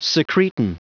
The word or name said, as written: secretin